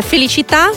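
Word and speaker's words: Felicità: Вы знаете, мне повезло, и в своей жизни я исполнил три композиции, которые, ударение в которые заканчиваются на «а», и ударение падает на эту последнюю «а». Это «Феличита», «Счастье», «Либерта», «Свобода» и «Чисара», «Будущее».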